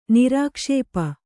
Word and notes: ನಿರಾಕ್ಷೇಪ: Kannada

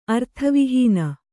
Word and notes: ಅರ್ಥವಹೀನೆ: arthavihīne

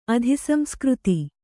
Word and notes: ಅಧಿಸಂಸ್ಕೃತಿ: adhisamsktritī

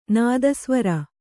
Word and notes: ನಾದಸ್ವರ: nāda svara

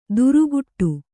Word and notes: ದುರುಗುಟ್ಟು: duruguṭṭu

